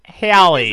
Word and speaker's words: Halle: H-A-L-L-E. – Spelled Halle. – It's an I-E, isn't it? – Ah, it's just E. You're right. – It's just E. It's pronounced Halle.